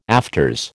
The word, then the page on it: afters: American English